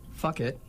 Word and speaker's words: Phuket: My beer is going to be, and now I must be very specific when I say this, it is spelled with a P-H. It is called Fuck It, P-H-U-K-E-T.